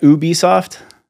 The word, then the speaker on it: Ubisoft: Ubisoft. Did I say that right? Ubisoft. – Yeah. Either. Ubisoft. I think both are correct.